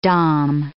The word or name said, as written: Dom